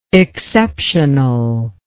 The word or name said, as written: exceptionalness